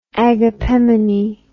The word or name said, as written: agapemone